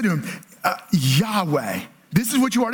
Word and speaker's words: Yahweh: And so it's often pronounced Yahweh or Jehovah.